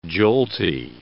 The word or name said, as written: jolty